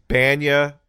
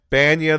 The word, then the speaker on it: Bania: And my name is Banya. Banya the Bender. – Like Banya from Seinfeld? – Yeah. – How do I spell that? – B-A-N-I-A.